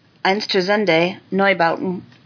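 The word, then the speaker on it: Einstürzende Neubauten: In music news, the iconic avant garde industrial German experimental band Einsturzende Neubauten.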